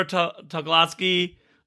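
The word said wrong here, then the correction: Tucholsky: I don't want to butcher her last name. I want to make sure I get it right. Sarah Tuchulski, thank you.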